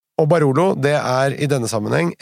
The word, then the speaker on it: Barolo: Jeg sier jo Barolo, men hvis man skal uttale riktig italiensk uttale, så er det Barolo.